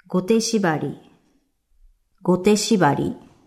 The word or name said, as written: Gote shibari